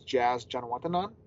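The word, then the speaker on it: Jazz Janewattananond: I mean, this, we work on a lot of pronunciations overall, but I know I nailed this one, and it's Jazz Janawatanan.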